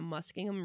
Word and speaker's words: Muskingum: So according to legend, three small children were playing in the Muskingum River near the bridge when they decided to venture out and explore the small islands near the center of the river. – That pronunciation of Muskingum was so smooth.